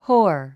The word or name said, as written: whore